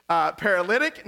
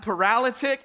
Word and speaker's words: paralytic: So it is paralytic, not paralytic, and